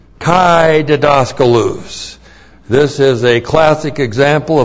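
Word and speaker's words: didaskalos (διδάσκαλος): Poimen, P-O-I-M, long E-N, poimen, and then didaskalos. D-I-D-A-S-K-A-L-O-S, didaskalos.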